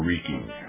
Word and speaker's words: Reiki: Reiki. – I could tell you the Japanese pronunciation. – What is the Japanese pronunciation? – Reiki. – Reiki? – Reiki.